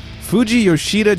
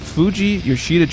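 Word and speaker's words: Fujiyoshida: Shout out to, boy, am I going to butcher this one, Fuji Yoshida Japan. – Nailed it right there. – Yeah. Fuji Yoshida Japan.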